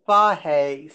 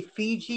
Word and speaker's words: Fage: I bought Fahe simply because people didn't know it at the time. It's spelled F-A-E-G-E. It's Greek. Fahe. – Yeah, F-A-G-E, right? It was F-A-G-E. – F-A-G-E, you're sorry.